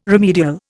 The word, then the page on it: remedial: Indian